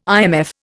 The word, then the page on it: IMF: Indian